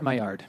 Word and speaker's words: Maillard: I'll just say it Frenchly. Maillard. – It's Maillard. – Yeah, there you go.